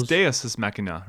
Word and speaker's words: deus ex machina: I think it's deus ex machina, right? – Okay.